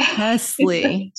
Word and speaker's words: Chesley: Chesley.